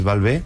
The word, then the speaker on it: Valvé: Y luego el acento en la E viene de mi apellido, que es Valvé, con acento, para así también darle un toque personal.